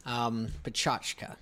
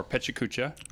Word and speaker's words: PechaKucha: Pechachka. – Or PechaKucha. – Or PikaChuka.